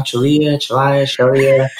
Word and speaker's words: Chelliah: Because there's like five different pronunciations of his last name. – Yeah, we can't decide ourselves. You know, my wife, when we were getting married, she was like, all right, so how do you pronounce this, all right? It's like, you know, Chelliah, you know, whatever works for you.